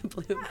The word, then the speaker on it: Blum: Bloom. Yeah. Well, it's spelled Blum, B-L-U-M.